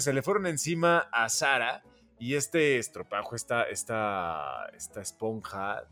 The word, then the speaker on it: Zara: que no se dice Zara, se dice Zara.